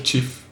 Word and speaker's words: GIF: Das heißt eigentlich GIF. – Eigentlich muss man das GIF aussprechen. – Ja, das eigentlich heißt das GIF. GIF. Weil der Erfinder das so gesagt hat auf einem Vortrag.